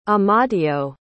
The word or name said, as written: amadeo